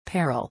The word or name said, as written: peril